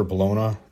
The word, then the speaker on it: bologna: The sausage traces back to Bologna, Italy, pronounced Bologna. When it came to the U.S., the product was Americanized and the word stuck with a unique pronunciation, dropping the G sound. So in the U.S., Bologna is bologna.